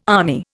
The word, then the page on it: army: Indian